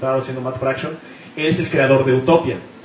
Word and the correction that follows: Utopia: Utopía, te lo dije mal, lo siento, soy un ignorante, gracias.